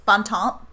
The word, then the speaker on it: Bon Temps: Bontemps. – Oh boy. – I have no Louisiana accent though, so I don't know if that's the Louisiana pronunciation. It's the HBO pronunciation.